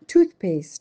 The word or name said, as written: toothpaste